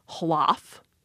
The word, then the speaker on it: hlaf: A loaf of bread was a hlaf, H-L-A-F.